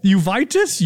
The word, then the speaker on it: uveitis: Uveitis? Uveitis? It's spelled U-V-E-I-T-I-S.